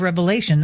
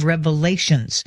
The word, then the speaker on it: Revelation: It's the book of Revelation, not the book of Revelations.